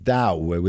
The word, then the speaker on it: Tao: I think they say it's pronounced Dao with a D.